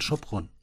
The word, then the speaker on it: Sopron: Eigentlich muss die, ich glaube, das spricht man dann Sopron.